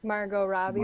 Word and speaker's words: Margot Robbie: Margot Robbie.